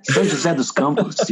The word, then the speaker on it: São José dos Campos: And he works at a church down in at Maranatha Baptist Church in São José de Campos. Did I pronounce that correctly? – Yes, it's understandable. Yeah, São José dos Campos, yes.